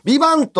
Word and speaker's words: VIVANT: ビバントって書いて、あ、その、あ、ビバントって英語で書いてビバンだよ。そう。あ、 t 発音せえへんやつ。